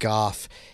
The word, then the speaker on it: Gough: It's actually pronounced Gough.